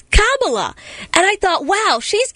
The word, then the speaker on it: Kamala: not Kamala.